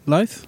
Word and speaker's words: Louth: Okay, so for some considerable time, the colonists remained surrounded by Gaelic-speaking communities in County Donegal to the west and the counties of Louth. How do you say it? – Louth.